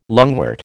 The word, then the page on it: lungwort: American English